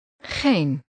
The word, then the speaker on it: geen: Geen. Een. Geen. Geen. – This word starts with a sound that doesn't exist in English. It resembles the ending of the Scottish word loch, but it's softer.